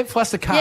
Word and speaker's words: fluster cluck: This could turn out to be Chris's fluster cluck. Is that the right? Am I saying it right? – Yeah, yeah, fluster cuck.